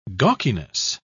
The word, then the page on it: gawkiness: British English